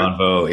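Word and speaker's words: Bonnevaux: I'm going to say it wrong, Bonvo, pretty good. – Bonvo, yeah.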